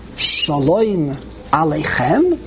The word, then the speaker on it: Shalom Aleichem: You say, Shalom Aleichem. How do you say it? – Shalom. – No. You're supposed to say, Shalom Aleichem? Question mark. That's what you're supposed to do.